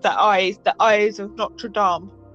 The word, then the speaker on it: Notre Dame: One thing I did love how they made Notre Dame, and that's actually how you pronounce it, Notre Dame.